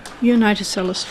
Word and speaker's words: Unitocellus: Unitocellus fulvus. – That was a magnificent pronunciation of that first bit, which is Unitocellus. That's really well done.